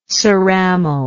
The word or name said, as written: ceramal